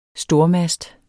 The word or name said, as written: stormast